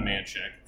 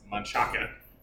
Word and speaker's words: Manchaca: If you were to say it correctly, you would say Guadalupe, but if you say it the way, it's just like Manchac. It's not Manchac, it's Manchaca.